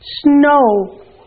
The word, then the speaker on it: snow: This OW letters makes the O sound. This is the ow. This is the O. Like in snow. The W is silent. Snow. Repeat snow.